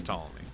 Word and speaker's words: Ptolemy: Ptolemy.